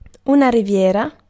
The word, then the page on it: coast: /kəʊst/